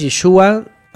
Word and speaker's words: Yeshua: O sea, su nombre es Yeshua, así, Yeshua. Incluso la, al principio es Yeshua, ¿no? Como si fuera I-E, es el sonido del, hebreo. No tanto Ye, no es Ye, así como pronunciado, sino Yeshua.